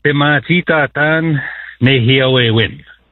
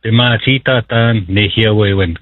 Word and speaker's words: Pimatitatan Nehiawewin: Keep Our Language Alive, the COLA conference, K-O-L-A. Mm-hmm. Will you give me the Cree pronunciation of this conference, please? – Yeah, it's Pimatitatan Nehiawewin. Pimatitatan Nehiawewin. Keep Our Language Alive.